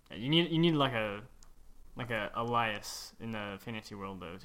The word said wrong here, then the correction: alias: Is that how you pronounce it? Elias? – Alias, yeah.